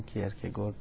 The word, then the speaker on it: کیرکگور: پس نظری اول نظری تبانان بود، که البته این نظری رو بعضی ها مثل کیر که گرد، خود تلفظ اسمش رو میگم، سورن کیرکگورد،